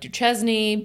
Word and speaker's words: Duchesne: Duchesne is spelled like Duchesne, but it's pronounced Duchesne.